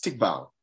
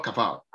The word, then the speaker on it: tikvah: The Hebrew word is tikval, not kaval. So I quoted the root. So the word is tikval, T-I-K-V-A-H, and it's pronounced tikval, tikval.